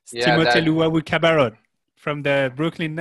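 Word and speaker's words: Luwawu-Cabarrot: Timothée Luwawu Cabarrot. – Luwawu Cabarrot.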